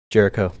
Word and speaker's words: Jericho: Jericho.